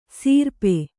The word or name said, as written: ಸೀರ್ಪೆ